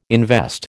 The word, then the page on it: invest: American English